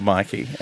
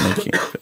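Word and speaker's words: Myki: The tram home with my Mickey. – In Victoria, we have a card called Mikey, and it's not pronounced Mickey. Thank you, Toby, for attempting that.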